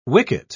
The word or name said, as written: wicket